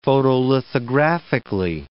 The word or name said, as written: photolithographically